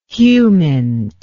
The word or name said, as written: humint